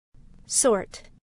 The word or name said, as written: sort